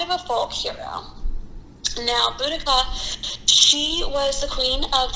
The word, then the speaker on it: Boudica: Now, for years I've said her name incorrectly. I've said Boudicca or Boudicca, and some people might consider that a proper pronunciation, but in my reading and my research, I have actually found that the proper pronunciation of it is Boudica. And the reason for that is that it's not English. It is, it is Iceni.